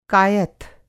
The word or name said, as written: कायथ